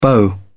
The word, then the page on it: beau: British English